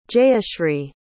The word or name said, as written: jayashri